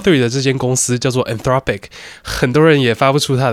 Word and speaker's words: Anthropic: 然后这间公司呢， A-N-T-H-R-O-P-I-C，不是念作 entropic 或是 entropic，我不知道大家怎么念，反正我听过很多种念法，但它的真正念法呢是 anthropic。